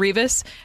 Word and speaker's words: Rivas: How do you guys feel about Alfonso Rivas? Is that how you Rivas. – Say his name? Rivas.